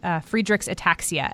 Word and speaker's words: Friedreich's ataxia: And that is your recent diagnosis with Friedreich's ataxia.